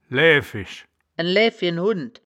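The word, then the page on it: läääfisch: Wittlicher Platt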